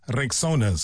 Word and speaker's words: Rexona: It's pronounced Rexona. Simple, strong, and easy to remember.